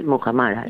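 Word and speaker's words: Muhammara: ¿Muhamara? ¿Lo digo bien? – Sí, Muhamara. Sí, Muhamara.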